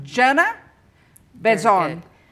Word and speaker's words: Jana Benzon: Okay, so we now begin our interview, and my guest is, tell me if I say your name right, Jana? Benzon.